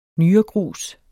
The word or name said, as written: nyregrus